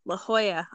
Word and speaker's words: La Jolla: It's pronounced La Jolla. La Jolla. Okay.